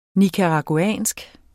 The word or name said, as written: nicaraguansk